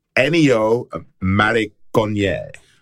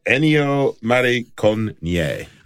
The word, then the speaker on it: Ennio Morricone: Just for any of his fans out there who are currently shouting at us, you know, shouting at their radios. It's Ennio Morricone. Ennio Morricone.